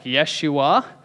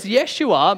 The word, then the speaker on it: Yeshua: Now, when we meet Jesus, we're all going to have to apologize for incorrectly pronouncing his name for the last 2,000 years. It's probably more pronounced something like Yeshua.